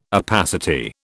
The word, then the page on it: opacity: British English